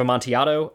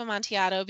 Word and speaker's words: Amontillado: I read The Telltale Heart, and I read The Cask of Amontillado, another story that I've read many times and which I thoroughly love. And also, I pronounced correctly this time.